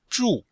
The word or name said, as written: zhu4